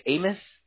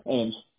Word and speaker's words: Ames: As far as your service area goes, do you only cover Ames, or how do you pronounce that? Amos? – Yeah, Ames. – Ames.